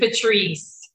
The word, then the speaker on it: Patrice: Patrice. – Yeah. Okay. – Yeah, rhymes with niece.